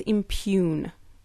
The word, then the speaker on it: impugn: The word was impugn. – Impugn. It was the G, right? – It was the G. – There you have it. At age 12, a little girl was attacked by a silent G, and she lived to tell the tale.